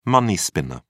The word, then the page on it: money-spinner: British English